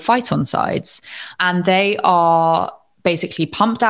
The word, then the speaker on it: phytoncides: Yeah, phytoncides, which are these volatile organic compounds which are released by trees.